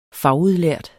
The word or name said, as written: fagudlært